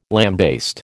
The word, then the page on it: lambaste: American English